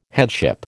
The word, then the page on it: headship: American English